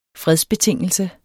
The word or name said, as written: fredsbetingelse